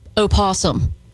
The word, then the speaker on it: opossum: I don't like that you say opossum. – I'm sorry. Hold on. Hey, they possums. – They are. The O is silent.